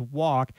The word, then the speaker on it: walk: She mentions walk. The L is there because we used to pronounce it with the L.